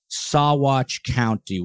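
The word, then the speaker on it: Saguache County: So Colorado Bureau of Investigation confirms that the remains found in Sawatch County, which is how I believe you pronounce it. Someone wrote that to me to let me know.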